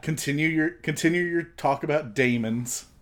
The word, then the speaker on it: daemons: If you look at it, again, from the Carl Jung sense, the daemons, which is a different form of... it's just different from demons. D-A... – You're saying that like Cheats McGee would say that. – Well, no, it's pronounced that way. D-A-E-M-O-N is...